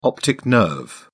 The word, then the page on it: optic nerve: British English